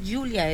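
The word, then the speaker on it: Giulia: Ah, y la chica es una niña porque es ¿cómo se pronuncia? Julia. Ah, bueno, se escribe con G. – Por eso dice. Sí, es que es Julia, es un nombre italiano como ahí.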